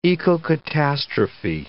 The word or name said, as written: ecocatastrophe